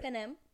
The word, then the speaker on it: Panem: How do you say it? – Panem.